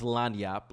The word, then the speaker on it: lagniappe: So I'll start by saying it is pronounced Lanyap. Lanyap, maybe? Lanyap? But it is spelled Lagniappe.